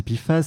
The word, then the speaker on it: PFAS: Et donc, pour commencer, de quoi parle-t-on Qu'est-ce que c'est que les PIFAS alors, les PFAS, les PFAS, donc à prononcer PFAS, c'est déjà quatre lettres pour une famille de produits chimiques franchement pas facile à prononcer.